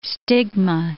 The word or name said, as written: stigma